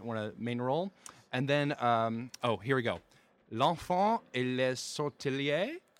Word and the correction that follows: sortilège: Sortilège. – Sortilège.